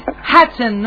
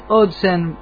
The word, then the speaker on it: Hudson: Rubén, me gustó porque de a vos aprendí que es Hudson, no es Hudson. No, como se, sea, Hudson, Hudson, Hudson, como como como cada uno quiera eh, pronunciar.